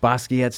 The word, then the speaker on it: Basquiat: Like, I get a little bit in that Basquiat stuff. That's how you say his name.